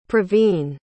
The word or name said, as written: praveen